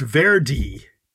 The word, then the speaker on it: Verde: And they're saying in the local Criollo dialect, it's pronounced Verde.